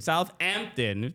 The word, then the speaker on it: Southampton: You don't pronounce the H. Because if you do, th is the T and the H. So you've already used the H. – You're gonna be like Jamal, bro. What are you doing? – No, it's not even about their dumb language that they don't pronounce half the stuff. – Southampton. – Yeah, you use the H already.